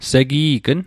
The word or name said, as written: segi'gn